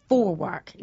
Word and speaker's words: Vorwerk: Vorwerk. Now, yeah. I know that's how you pronounce it because I used to live in Germany.